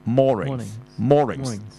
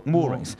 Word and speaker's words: Moorings: 那下面那個字呢，又不同了，其實一樣串法，但是意思不同，但是讀音就一樣的，就是叫 Moorings，就唔好讀 Moorings。